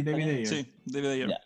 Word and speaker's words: David Ayer: Y el director, eh, el director, no sé si estará bien, bien, voy a pronunciar bien el apellido, es David Ayer, parece. – sí, David Ayer.